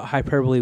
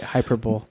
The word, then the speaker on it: hyperbole: Cognitive dissonance, I've heard a lot of people that thought hyperbole was hyperbole. – Yeah, that's poop too. – That's how you say it, hyperbole. – And it kind of like hyperbolic. So then you're. – So yeah, right. It's definitely hyperbole.